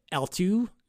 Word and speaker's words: eltoo: So this brings us to SigHash AnyPrevOut, which enables something that is called L2, right? And it's a funny name because it's like layer 2 L2, but it's spelled ELTOO.